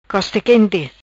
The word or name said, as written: Costa Quenti